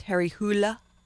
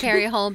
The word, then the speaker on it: Harry Hole: And his name's supposed to be pronounced Harry Hula, because he's Norwegian or... something like that. – I like Harry Hole better. – Harry Hole is the way that the announcer does it for us Americans, because...